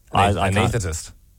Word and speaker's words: anaesthetist: Yeah, Liam's right, anaesthetist. I think you're overthinking the S in it. – No, there is an S in there, right? – Yeah, but you're overthinking it. My wife's an anaesthetic nurse. That's her whole job. – Anaesthetist. – Yeah, you don't have to pronounce that. You just say anaesthetist.